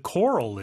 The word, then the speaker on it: coral: The coral is so colorful. – The coral is so colorful. – 산호가 뭐라고요? – Coral. – 네, coral입니다. 우리가 코랄이라고 하는데 발음은 좀 다르죠?